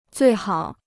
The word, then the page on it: 最好: zuì hǎo